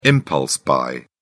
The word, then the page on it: impulse-buy: British English